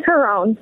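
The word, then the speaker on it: Carone: It's Melissa Cherone. Did I say it right? – It's Caron. – Oh, Caron.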